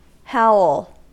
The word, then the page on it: howl: American English